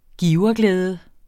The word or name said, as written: giverglæde